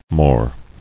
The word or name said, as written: More